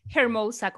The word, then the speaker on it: Hermosa: Hermosa, con J, la H, porque la pronuncian.